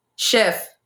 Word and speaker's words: Chef 187: is it Chef or Chief? – Chef. – Chef 187. – Yeah.